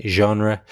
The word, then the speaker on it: genre: And the pronunciation is a little bit tricky. It's one of these words that comes from French and is kind of pronounced in a French way, genre.